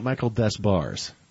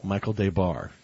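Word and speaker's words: Michael Des Barres: It's not Michael Desbars. – No. – Michael Debar is how it's pronounced.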